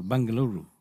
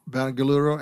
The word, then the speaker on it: Bengaluru: Bengaluru. – Bengaluru and Chennai.